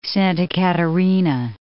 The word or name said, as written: Santa Catarina